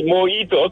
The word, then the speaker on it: mojito: That thing is not mojito. There's no J. The J is silent.